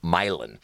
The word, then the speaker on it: Milan: Funny enough, that band, and it's spelled Milan, but it's actually a Czech name, and it's Milan. It's Milan and the Sour Goat.